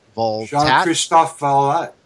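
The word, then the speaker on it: Jean-Christophe Valtat: And I'll try and pronounce his name. It's John Christopher Vault. – Jean-Christophe Vallat. Yeah.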